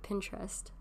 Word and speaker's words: Pinterest: You can scroll through your Instagram as much as you want, or your Pinterest, that's how you say it, right? Pinterest, yeah.